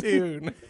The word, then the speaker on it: Pewn: P-E-W-N. Poon.